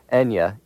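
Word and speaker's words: Eithne: Enya, E-N-Y-A, is the way she's now spelling it. In the original, it's E-I-T-H-N-E. Either way, it's pronounced the same.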